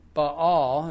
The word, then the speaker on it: Baal: who withstood the priest of Baal, is the correct pronunciation.